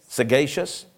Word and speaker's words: sagacious: am I saying that right? – Sagacious. – This is my professor here, a professor of English, sagacious. I thought it was a silent G. I thought the root was Latin, you know, I know all about it.